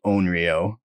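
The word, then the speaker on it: onryō: The book has a pronunciation guide. O's make the OH sound, so it would be Onryo.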